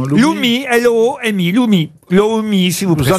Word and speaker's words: loomi: Ça vient d'Iran, ça s'appelle le Loumi, mais de quoi s'agit-il? – Un missile balistique? – Non. Ça s'écrit L-O-O-M-I.